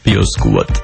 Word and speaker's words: Piosquat: Ako sa to vyslovuje? – Piosquat. – Squat alebo squat? – Squat.